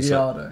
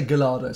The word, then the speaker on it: Gallardo: Gallardo. – So. If you say Gallardo in an Italian sort of accent, then you can get away with it. – Oh, maybe I say Gallardo sometimes.